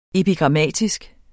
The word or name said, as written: epigrammatisk